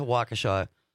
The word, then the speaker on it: Waukesha: and a big shout out to Waukesha for all the mentions. He never made it out there, though. – Pronounced correctly every single time.